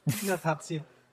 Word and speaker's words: Dilatation: Dilatation.